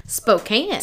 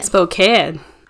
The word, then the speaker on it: Spokane: Spokane. – Spokane.